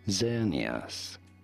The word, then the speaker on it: Xerneas: This is to be said as Xerneas. Xerneas, not Xerneas. – Not Xerneas. – Z or Z sound. At the beginning, Xerneas.